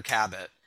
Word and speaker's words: Cabot: Now, just a note, they were pronouncing her last name with a hard T the entire time, Alexander Cabot. And I'm almost certain that the film pronounced it Cabot, which is what I've always called her.